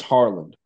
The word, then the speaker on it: Haaland: It's pronounced Harland, but that's okay. – Harlan, like Kevin Harlan. – Harland, like Harland. H-A-H-A-R. It's Haaland, but he pronounces it Harland, like it with an R.